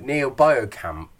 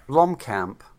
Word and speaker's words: Blomkamp: It's a short, 15-minute short by, I'm going to say this right, Neil Blomkamp. – Blomkamp.